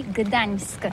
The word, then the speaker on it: Gdańsk: Ça s'écrit G-D-A-N-S-K. On dit Gdansk ou Gdansk, on prononce le G ou pas? – Oui, c'est pas très facile pour des étrangers, mais remarque bien que la langue polonaise, c'est la langue où on prononce chaque lettre qu'on écrit. C'est pourquoi on dit Gdańsk.